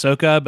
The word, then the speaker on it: Sokka: Well, actually, it's pronounced Sokka, but that's fine.